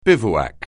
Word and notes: bivouac: anglais

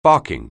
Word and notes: barking: anglais

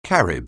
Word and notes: Carib: anglais

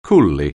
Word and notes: coolly: anglais

